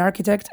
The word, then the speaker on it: architect: o que se pronuncie K como en architect,